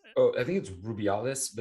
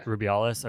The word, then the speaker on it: Rubiales: He's talking with RFEF president Luis Rubiales. I think I'm saying that correctly. – I think it's Rubiales, but yeah. – Rubiales, okay.